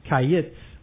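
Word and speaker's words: kayits (קיץ): So the word for fruit is kayits, Q-A-Y-I-T-S, and the word for end is kets, K-E-T-S, Q-E-T-S. So kayits and kets, they sound very familiar, very different words, summer fruit and end.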